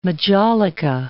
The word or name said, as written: majolica